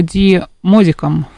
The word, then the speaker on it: Ди Модиком: Это бронзовая скульптура, которая была создана итальянским художником Артуро Ди Модиком в 1989 году. Надеюсь, я правильно поставила ударение. – Да, вроде правильно.